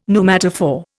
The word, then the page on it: pneumatophore: Indian